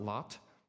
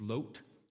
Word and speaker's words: Lot: And Nick um, did a great job last week of actually teaching us the, pronect, the correct pronunciation of the name Lot, that it's not Lot, it's Lot, just like the name Job is Job, not Job. And so Lot is Lot.